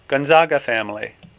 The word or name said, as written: Gonzaga